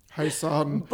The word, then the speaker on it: Joseon: Beauty of, I want to say, Hosan. – Hosan. – Like Hoseon, but it's Korean and not Spanish.